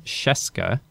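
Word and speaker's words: Scieszka: New at number 10, John Sheska, tricky name to pronounce, but a familiar name on the list.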